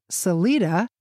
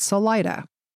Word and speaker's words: Salida: Although the Spanish pronounce it Salida, the residents always pronounced it Salida.